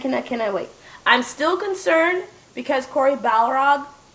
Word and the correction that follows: Barlog: Barlog. I always say his name wrong because I think of Balrog from Street Fighter.